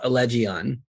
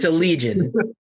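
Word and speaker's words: Allegaeon: Don't feel bad about Allegion or Legion. It's a Legion.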